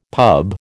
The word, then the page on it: pub: American English